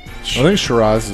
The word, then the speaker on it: Shiraz: I'm pronouncing this wrong. I know I am. It's a type of wine. – No, I actually think you are saying it Shiraz? – Right. – Shiraz? Okay.